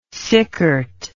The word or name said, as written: sickert